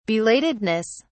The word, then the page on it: belatedness: British English